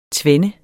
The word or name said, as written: tvende